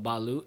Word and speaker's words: balut: Wait, so for the listeners that don't know what balut is... – Explain. – Is that how you say it? – Yeah, there's a number of different ways you can say it, but okay. That's one of them.